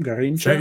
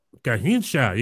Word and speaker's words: Garrincha: You think you're talking about how do you say his name? Garrincha? – Yo, you got it. Garrincha.